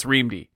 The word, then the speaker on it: REAMDE: Anyway, today we are going to talk about REAMDE, which is how you pronounce it. By the way, we checked. Neal Stephenson has a video where he calls it REAMDE. It's REAMDE. It's README, misspelled as REAMDE by Chinese...